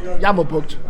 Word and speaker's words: Jammerbugt: Go on, how do you pronounce it? Go on. – Jammerbugt. – Jammerbugt. Okay, well, it's close. Not bad. Yeah, it's not bad.